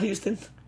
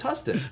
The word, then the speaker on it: Huston: Not Houston? – No, it's Huston.